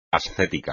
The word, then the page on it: ascético: espagnol